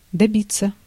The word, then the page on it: добиться: /dɐˈbʲɪt͡sə/